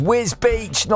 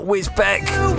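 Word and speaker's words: Wisbech: Hello to Paul, who says, Tim, it's pronounced Wisbeach, not Wisbeck.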